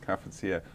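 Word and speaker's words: cafetière: Pronounced cafetiere. Apparently, it's the correct way of saying it.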